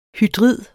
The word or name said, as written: hydrid